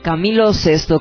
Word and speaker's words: Camilo Sesto: ¿Cómo se pronuncia entonces? Camilo Sesto con S y nada más.